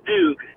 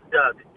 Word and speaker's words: Duke: Secondly, it's pronounced Duke, not Doug. Duke.